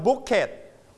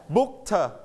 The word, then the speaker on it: booked: So when we put ed, we don't pronounce bucket, we don't pronounce packet, and we don't pronounce hiket. We pronounce t. Okay. Everybody t. – T. – Booked.